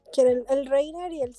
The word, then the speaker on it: Reiner: Seque y el Reina, Reina, así lo dicen ellos, pero pues no sé. se escribe Reiner.